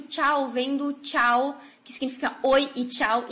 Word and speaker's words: ciao: que se escreve C-I-A-O, tá? Ele, então, transformaria-se, né, em tchau, que o CH, esse som que no italiano só o C tem o tch, o som de tch, no espanhol é o CH, por isso fica tchau, C-H-A-O.